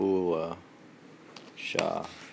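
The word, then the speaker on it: Bulleh Shah: B-U-L-L. – B-U-L-L. – B, like the bull. Bull. Mm-hmm. Bullhe Shah. Bull.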